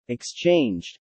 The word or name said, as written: exchanged